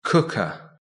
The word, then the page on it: cooker: British English